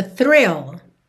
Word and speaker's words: thrill: Aqui não tem muito mistério, é, fique aí atento, atenta às diferenças de pronúncia de fill, que é com F mesmo, e thrill, que começa com TH, th, th, th, linguinha lá na frente, é, lá nos dentes da frente, e tem R, thrill, ok?